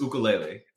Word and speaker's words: ukulele: You're so talented with your ukulele. That's the proper pronunciation. – Actually, no joke, it actually is. Okay. The Hawaiian pronunciation is ukulele.